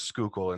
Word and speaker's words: Schuylkill: you know the harshness of showing somebody the word Schuylkill and asking them to pronounce it. – Yes, absolutely. You notice I got it exactly right.